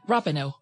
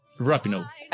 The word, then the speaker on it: Rapinoe: Rapinoe. – Rapinoe.